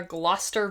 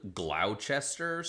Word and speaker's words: Gloucester: For the audience who is nowhere near Virginia and might be Googling that, it's spelled Gloucester, so, like, good luck with that.